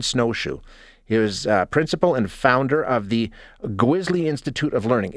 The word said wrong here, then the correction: Gwizhii: Did I say that correctly? Gwisley? – It's actually Gwiji. – Gwiji. Okay, I'm sorry. – It's okay. It's spelled G-W-I-Z-H-I-I.